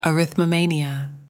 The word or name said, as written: arithmomania